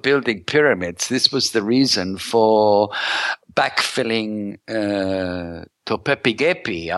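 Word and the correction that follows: Göbekli Tepe: I, I probably s- pronounced that wrong because Gobekli Tepe. – Yeah, that's the place.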